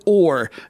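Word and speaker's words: Hore: I'm going to pronounce it Ore. It's spelled H-O-R-E. I'm just wanting the H to be silent for obvious reasons.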